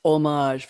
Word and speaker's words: homage: It's a parody of, in an homage, you're not supposed to pronounce the H, Anissa, we know that, right? It's not homage, it's homage, very French, to sci-fi films and series, especially Star Trek and its fandom.